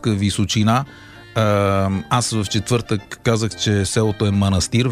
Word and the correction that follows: манастир: Веднага Михаил Делирадев ме контрира с: абе, знаеш ли, че ударението е манастир? – Ами това е като винарска изба Драгомир.